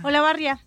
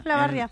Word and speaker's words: Olavarría: ¿Cómo se pronuncia? Olavarría. – Olavarría. Ajá. Olavarría.